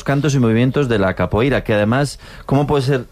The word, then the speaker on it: capoeira: Pues todos sabemos lo que es la copeira, ¿verdad? – Capoeira. – Qué mal pronuncio últimamente. – Capoeira, ¿no? – Capoeira.